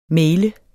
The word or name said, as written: maile